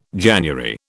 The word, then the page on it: January: British English